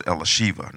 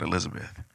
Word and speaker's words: Elisheva: My daughter Elizabeth, her name is pronounced Elisheva, not Elizabeth.